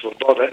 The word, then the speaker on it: Свобода: Не съм убеден, че партията Свобода, както е наричат по-украински с ударението Свобода, или пък десния сектор, така са много горещи, как да кажем, твърди привърженици на демокрацията.